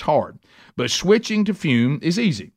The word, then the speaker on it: Fum: That's T-R-Y-F-U-M. It's pronounced Fume, but it's spelled F-U-M.